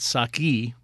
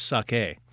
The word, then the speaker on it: sake: It's like the word socket without the T. So it's not sake the way most folks pronounce it. It's sake.